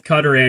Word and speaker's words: Qatar: Technically, both work. Technically, both Qatar and Qatar.